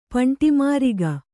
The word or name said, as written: ಪಂಟಿಮಾರಿಗ